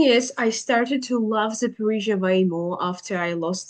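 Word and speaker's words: Zaporizhzhia: So you're from Zaporizhia originally. – You pronounced it very well. I'm impressed.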